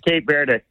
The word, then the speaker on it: Cape Verde: Either way, you said it right. That's the it's kind of like Turkey, Turquia. – Yeah. – They in Spanish, it's Cabo Verde. – Cabo Verde. – Cabo San Lucas or whatever in Mexico. Cabo Verde. But okay. The English pronunciation is Cape Verde.